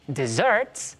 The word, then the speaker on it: desserts: Y con el acento acá, en esta parte, desserts, tenemos los que compramos cuando vamos de viaje después del almuerzo, por ejemplo. – Ah, mira, pues lo mira. Que tú decías de la pronunciación, esos pequeños detalles pueden significar mucho, o que compras un postre, o que te compras un desierto.